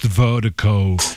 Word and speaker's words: Virdiko: And Vertico is spelled V-I-R-D-I-K-O. That's V-I-R-D-I-K-O, pronounced Vertico.